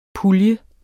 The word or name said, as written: pulje